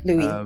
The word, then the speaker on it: Lewis: Louis.